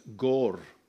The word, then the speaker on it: Gore: Many years ago, we had a, a couple in our church who came from, I never know how to say this, Gore, is that right? In the South Island of New Zealand. And you have to roll the R somehow on the way through.